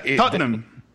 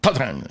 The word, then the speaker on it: Tottenham: Tottenham. – Tottenham. – Son dos sílabas. – Es difícil, ¿no? – Nosotros los británicos. Pero, ¿solo dos sílabas? – Sí, Tottenham. Tottenham.